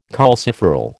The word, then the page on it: calciferol: American English